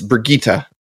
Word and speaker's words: Brigitte: I want to make sure, like, because we always say her name wrong. Everybody does. It's Brigitte.